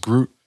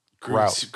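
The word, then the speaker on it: gruit: So apparently beer made without hops, it's not pronounced Groot. It's pronounced Grout.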